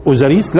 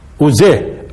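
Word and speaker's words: أوزير: هل كانوا يقولوا اوزاريس لا كانوا يقولوا لحظه اوزير اوزير اوزير اوزيريس الياء والسين هاي دخلوا دخلوها هذه بلغه مين؟ بلغه اليونان وخصوصا اليونان السين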